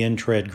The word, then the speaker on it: NTRAD: The issues faced by the NTRAD group, N-T-R-A-D, pronounced NTRAD, of non-traditional owners are unique to these special venues.